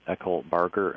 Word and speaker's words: Eckelberger: Our guest, as I said in the introduction, is Gary Eckelberger.